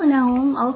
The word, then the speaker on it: Nahum: N-A-H-U-M. – Oh, Noun.